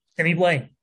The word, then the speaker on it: Blais: Sammy Blais. Like how you know? I think it's a silent S, right? – Yeah.